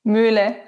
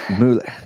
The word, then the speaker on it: Muehle: And did I say it properly? – Muehle. – Muehle. Okay. I've been struggling.